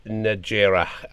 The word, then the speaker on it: Najera: I am delighted to be joined by Mario and Nancy Najera.